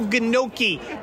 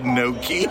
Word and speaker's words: gnocchi: The fake Chuck Johnson is like little gnocchi on a bed of shredded red cabbage. – Did you say gnocchi? – Gnocchi. – I don't think you pronounce the G in gnocchi.